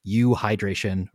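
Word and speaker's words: euhydration: And again, this is U hydration or U hydrated, which is spelled EU hydration.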